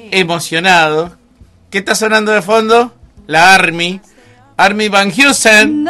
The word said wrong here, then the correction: Amy Winehouse: No, Army Van Heusen no. – ¿Cómo se dice? No me sale a mí el... – Amy Winehouse. – Dígamelo de nuevo, por favor, porque así lo memorizo. – Amy Winehouse.